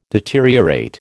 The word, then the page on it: deteriorate: American English